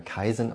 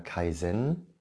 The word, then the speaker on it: Kaizen: Es wird übrigens manchmal Kaizen ausgesprochen und manchmal Kaizen, also auf Zen die Betonung.